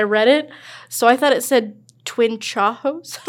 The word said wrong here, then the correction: chaos: But it's Twin Chaos.